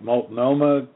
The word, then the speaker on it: Multnomah: if I pronounce it right, Multnomah County, Oregon? – Correct.